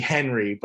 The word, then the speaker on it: Henri: It's actually Henry, but if you look at how it's spelled, it's H-E-N-R-I, which is more the like French version.